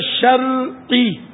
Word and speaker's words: شرعی: صحیح تلفظ ہے شرعی، را ساکن، شرعی شرعی شرعی شرعی شرعی رہنمائی، ہمارے یہاں بولتے شرعی رہنمائی، سب تقریباً 99.99 بولے تو شاید غلط نہ ہو.